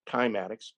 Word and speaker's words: cymatics: Actually, in Greek, you can pronounce it chymatics, believe it or not.